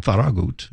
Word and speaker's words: Farragut: So it's and then you had people like Farragut, who pronounced his name Farragut, who was from Spain. You know, so it was a place we're calling it a city with a dozen accents. – I think we need to bring that pronunciation back. – Yeah. – At least you and I spearhead this. Yeah. It's no longer Farragut. It's Farragut. – Farragut.